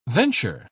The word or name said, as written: venture